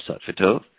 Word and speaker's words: satvitov: Satvitov, that's composed of two words from ancient wisdom traditions.